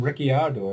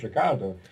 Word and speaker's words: Ricciardo: Everybody calls him Ricciardo, and that's just not his name. I know it looks that way, but it's not. His name's just Ricardo.